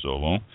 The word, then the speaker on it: Zolo: The gold, they gave a gold to Zolo, or I believe it's pronounced Zolo, Z-O-L-O, Zolo Malbec Reserva. It's one from Tapez, T-A-P-I-Z, Tapez Winery.